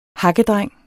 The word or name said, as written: hakkedreng